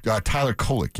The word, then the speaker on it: Kolek: Tyler Kolek, yeah.